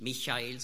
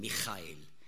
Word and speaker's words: Michael: Darum sage ich eben oft, wenn ich Hochdeutsch spreche, nicht Michael, sondern Michael. Nicht, weil ich gerne Schweizer Hochdeutsch spreche, sondern das ist die richtige Aussprache.